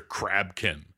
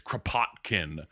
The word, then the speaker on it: Kropotkin: That clearly wasn't his name. It's like, it's Kropotkin. – Mm-hmm. – And they clearly say it like every time when she like calls the embassy or goes into the meeting with him. And it's like, yes, this is Kropotkin. And then when she calls, she's like, Mr. Krabkin, Mr. Krabkin.